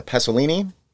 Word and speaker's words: Pasolini: Pasolina, uh, uh, Pasolini, Paolo Pasolini. I'm bad with my Italian pronunciations. A lot of vowels. It's hard for me to pronounce.